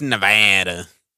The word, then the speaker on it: Nevada: It's Nevada. That's hmm. just you said it correctly.